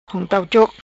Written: Hong Tau Choc

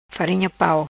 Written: farinha pau

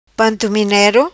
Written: Pantominero

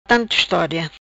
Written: Estoria Tanto

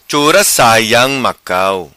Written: Saiam Chura Macau

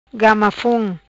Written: Gamafon

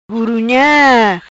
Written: Gurunha

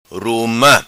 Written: Ruma

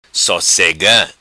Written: Sosega

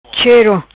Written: Chero

